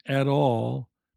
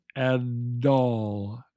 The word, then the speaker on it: at all: It's spelled B-U-T-T-E-R, butter. But we say budder, budder, a D sound. And at all becomes ad-all, ad-all, not at all.